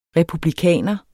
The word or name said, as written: republikaner